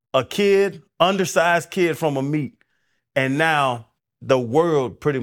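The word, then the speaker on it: Amite: I was gonna say a mite. It's a meet.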